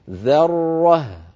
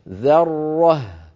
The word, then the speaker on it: ذرة: لابد من ترقيق الذال والهاء وتبيين الراء المفخمة بينهما هكذا ذرة ذرة.